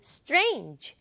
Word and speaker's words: strange: 영어로는 strange 한 번에 발음한다는 거. 이거 우리 친구들 연습해 보고요. 소리는 st, r 첫 소리에 세 개의 소리가 들어있어요.